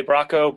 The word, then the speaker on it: Bracco: Bracco. Bracco works.